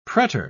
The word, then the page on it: pretor: British English